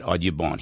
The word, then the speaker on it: Audubon: although he might have pronounced it Audubon. He was French-speaking.